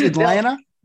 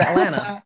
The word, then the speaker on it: Atlanta: First thing is don't pronounce the second T in Atlanta. – That's it? It's Atlanta? – It's Atlanta.